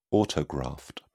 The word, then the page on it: autograft: British English